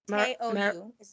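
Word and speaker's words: Teo Yoo: I thought the cast, Greta Lee, T.U., I did not look up how to pronounce his name, T.U. and John Marago. – Teou is how you say his name. – Teou, thank you.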